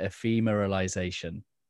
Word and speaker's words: ephemeralization: And there was this one phrase, I'm probably going to pronounce it wrong, but ephemeralization. Does that sound familiar? Did I pronounce that right? – That's very good.